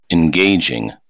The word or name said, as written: engaging